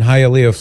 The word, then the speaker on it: Hialeah: Gilberto in Hialeah, Florida. Did I say that right, Mary? – Yes, Hialeah? – You got it.